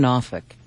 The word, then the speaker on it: Norfolk: Norfolk.